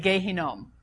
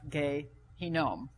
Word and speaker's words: Gehinnom: Now, I've just made a note of the fact that in Hebrew, to say Valley of Hinnom is saying Gehinnom. That's how you say it, Valley of Gehinnom.